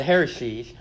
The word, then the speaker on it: heresies: but also so that we might answer the hearsays, the heresies, I always say that wrong, the heresies that are found among us today.